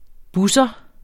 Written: busser

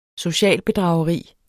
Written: socialbedrageri

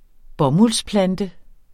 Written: bomuldsplante

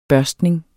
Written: børstning

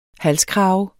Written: halskrave

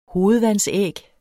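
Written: hovedvandsæg

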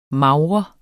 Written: MAGRER

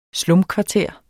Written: slumkvarter

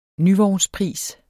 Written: nyvognspris

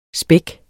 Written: spæk

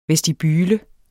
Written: VESTIBULEN

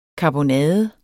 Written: karbonade

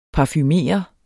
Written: parfumere

